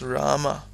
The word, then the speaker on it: Rama: Det skal endvidere siges, at i mantraet, der staves ordet Ram, det staves Rama. Rama. Men det udtales Ram med et stumt dag til sidst og med et langt af i midten.